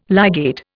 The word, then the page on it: ligate: Indian